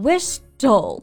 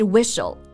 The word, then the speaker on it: whistle: 对，像这个 s t l e 结尾的，比如说 whistle 哨子，或者说吹口哨，很多同学看到拼写呢就会读成 whistle，对吧？但是是 whistle。